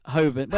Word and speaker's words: Holborn: Hoban. – Well, you say Hoban, I say Hoban. – It's Holborn. I say it correctly.